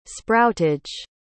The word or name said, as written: sproutage